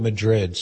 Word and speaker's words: Madrid: And by the way, my last name is spelled Madrid.